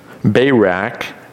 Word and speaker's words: Barak: And she calls the leader, Barak, the leader of the army of Israel, Barak, is how his name is pronounced.